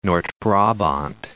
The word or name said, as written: Noord-Brabant